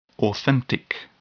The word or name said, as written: authentic